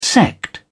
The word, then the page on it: sect: British English